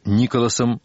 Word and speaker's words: Николасом: Доминик, очень энергичная женщина средних лет, вспоминает о своем знаменитом муже, которого называют Николасом, с ударением на первом слоге, с теплотой и юмором.